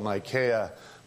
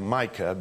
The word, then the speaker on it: Micaiah: You know, there are people that still will not call Micaiah, but they'll call him Micah because Brother Branham mispronounced Micaiah's name and called him Micah. And they said, well, Micaiah's name must have really been Micah, or Brother Branham wouldn't have said that.